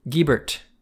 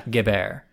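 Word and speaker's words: Guebert: Which is silly, but like, the way that we're pronouncing it is the Americanized version of a French name. And the answer is that we are pronouncing it Guibert.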